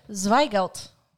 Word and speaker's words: Zweigelt: It's a Zweigelt. Did I say that right? – My German's not great, but that's how I would have said it.